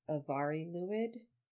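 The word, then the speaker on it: Y Fari Lwyd: Well, so it says the Mari Lued, but then it says in parentheses Welsh Avari Lued, because those first, like, the is, you know, an English word, and in Welsh that's just the letter Y, and then Mari is, like, originally spelled with an F in Welsh, I guess, but it's pronounced like a V.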